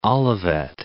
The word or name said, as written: Olivet